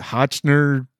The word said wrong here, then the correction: Hoechlin: However you say his name. – Tyler Hoechlin. So yeah.